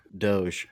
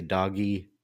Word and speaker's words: Doge: I love that you're saying it properly, Doge, because for years, 50% of people would say doggy or doggy or something like that.